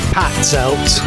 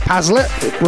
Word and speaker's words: Patzelt: Ross Pazelt. It's actually Ross Patzelt. In the morning, reach on air. Be amazed how many people can't pronounce my name. Ross Pazelt, Ross Patzelt, Ross Pate.